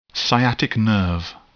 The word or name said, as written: sciatic nerve